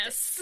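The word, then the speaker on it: Annas: Anus. it really sounds like a fancy way of saying anus.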